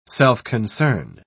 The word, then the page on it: self-concern: British English